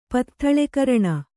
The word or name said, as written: ಪತ್ಥಳೆಕರಣ